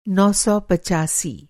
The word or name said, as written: नौ सौ पचासी